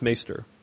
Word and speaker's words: maester: Jonah, it's pronounced maester. I just want to say that. – Master? – Nice. Maester. It's maester.